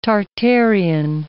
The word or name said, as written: Tartarean